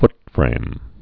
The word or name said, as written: footframe